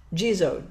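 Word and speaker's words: Jizō: The Gisho rocks that we were going to paint in May, did that? – Gisho. – What is it? – Gizo, J-I-Z-O. – Gizo.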